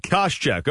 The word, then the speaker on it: Koscheck: The welterweight bout, Josh Koscheck. Am I saying that right? – Yeah, you deliberately butchered that because I know you know who this guy is. – I really don't. – Koscheck. – Okay, yeah.